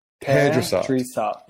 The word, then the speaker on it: Perdrisat: No, Perdrisat.